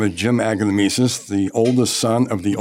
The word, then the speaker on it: Aglamesis: It is Aglamisis. It's phonetically exactly as you see it spelled, A-G-L-A-M-E-S-I-S, Aglamisis. And we have a lot of fun listening to it. Our customers try to come up with their pronunciations. A lot of people give it the French twist, Aglamisis.